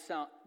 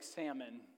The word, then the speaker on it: Salmon: And Amminadab, the father of Nashon, and Nashon, the father of Salmon. This is literally like salmon, but it's not. It's pronounced Salmon.